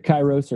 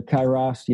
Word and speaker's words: Kairos: And, you know, we call it Kairos Partnerships, but Kairos or Kairos, you know, from the Greek word time. Either one.